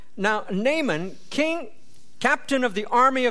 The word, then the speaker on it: Naaman: Actually in our language we would pronounce his name Naaman.